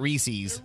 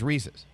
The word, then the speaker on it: Reese's: I love Reese's, even though Nate calls it Reese's. – It's not Reese's. My wife calls it that. It's not. It doesn't rhyme with feces. – It doesn't rhyme with feces. It's Reese's.